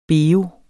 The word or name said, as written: BEOEN